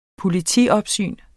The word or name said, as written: politiopsyn